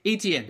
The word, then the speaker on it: Etienne: but Travis Etienne, did I say that right? Did I say it right? – Etienne, there we go.